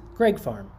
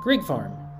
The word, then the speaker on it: Greig: Greig Farm, spelled G-R-E-I-G, is pronounced Greig Farm. Greig Farm. We've been calling it Greig Farm.